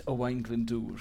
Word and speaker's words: Owain Glyndŵr: Uh, uh Owain Gwyndor. – Ah. it's, it's Owain Glyndŵr.